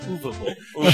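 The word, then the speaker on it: Uwe Boll: Seriously, folks, Shane's game movies are so bad, UA Bull formed an online petition to stop him. – It's pronounced Uva Bull.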